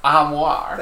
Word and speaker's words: armoire: I believe it's pronounced armoire.